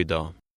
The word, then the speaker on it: idag: The G is silent. – Klockan 7 idag.